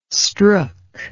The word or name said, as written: strook